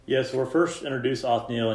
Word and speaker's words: Othniel: So first we've got, I think you pronounce it Othniel. Does that sound right? – Yep.